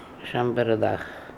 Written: chambardakh

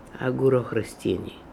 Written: Agoorokhrostiny

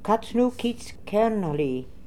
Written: katznookitzkernally

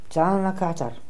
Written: Zalnakatar

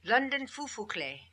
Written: London Phu Phu Kle

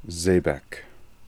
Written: Zehbek

